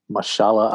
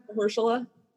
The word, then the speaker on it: Mahershala: Mahershala.